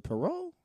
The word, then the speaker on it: Poirier: That's something I realized, like, when Conor lost to Poirier.